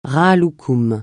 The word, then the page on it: rahat-loukoum: francais